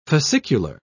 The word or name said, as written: fascicular